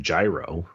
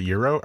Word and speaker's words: gyro: I mean, like you don't go around saying gyro, right? – No. – Okay. – Wait, how do you say it? Is it euro? I say euro. – Euro, yeah. That's how I believe that's how the Greeks pronounce it. Before any Greek listeners call me out, and I believe it's euro. – Yeah, but not hero.